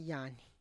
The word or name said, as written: yany